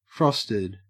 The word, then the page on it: frosted: Australian English